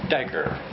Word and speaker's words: daqar: The word pierced comes from the Hebrew word D-A-Q-A-R, pronounced dagger.